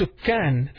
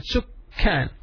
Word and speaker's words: سكان: The plural is sukkan, the inhabitants. سكان سكان سين كاف ألف النون. There is a shadda on top of the calf, so pronounce it سكان.